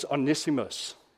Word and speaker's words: Onesimus: And it was Onesimus.